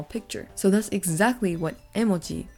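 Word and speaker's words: emoji (絵文字): The last one we have here is emojis. So this word is originally from Japan because Japan was the one who invented emojis. The pronunciation in Japanese is emoji.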